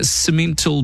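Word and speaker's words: Simmental: 20 yearling cemental bulls, that's how you pronounce it,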